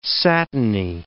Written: satiny

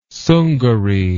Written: Sungari

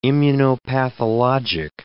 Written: immunopathologic

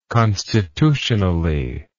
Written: constitutionally

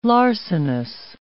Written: larcenous

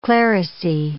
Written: clerisy

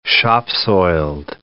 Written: shop-soiled

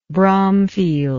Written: Bromfield

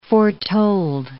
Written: foretold

